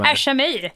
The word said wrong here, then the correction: ASMR: Nej, men det uttalas på ett annat sätt. Vänta. – Ellen? Ja? Vad heter det här när man pratar nära micken? Så här viskande och äter skit. ASMR heter det.